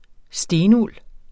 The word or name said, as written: stenuld